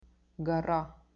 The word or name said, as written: gora